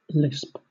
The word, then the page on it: lisp: British English